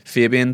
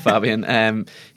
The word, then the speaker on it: Fabian: And I think Fabian Delph, as I've been told is how you pronounce his first name, it's not Fabian, he's come in and done really, really well on that left-hand side with Mendy being out.